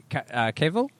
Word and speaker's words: Cavill: Henry, and I know this is the thing they all do, but Henry Cavill? That's how you say his name, mate.